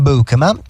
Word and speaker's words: Beukema: Beukema, poi si dice Beukema. Non Beukema, perdonate le di pronuncia, Sam Beukema, in olandese proprio così si dice.